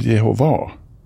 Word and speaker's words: Jehova: Du, hur uttalas det förresten? Jehova eller Jehova? – Ja, vittnen säger Jehova.